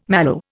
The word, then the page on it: mallow: Indian